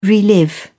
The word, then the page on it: relive: British English